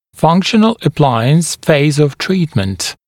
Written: functional appliance phase of treatment

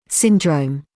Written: syndrome

